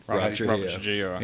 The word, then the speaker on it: Robert Trujillo: Robert Trujillo.